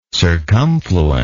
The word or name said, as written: circumfluence